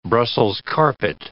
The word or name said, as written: Brussels carpet